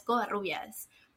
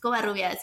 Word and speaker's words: Covarrubias: And so I have one of the hardest names to pronounce if you don't speak Spanish. And I had to stand up for myself and tell my professors, no, it's Covarrubias.